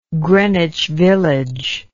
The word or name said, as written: Greenwich Village